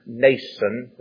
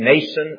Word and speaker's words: Mnason: There went with us also certain of the disciples of Caesarea, and brought with them one Mnason, that's pronounced just as though it didn't have an M on it, one Mnason of Cyprus, an old disciple with whom we should lodge.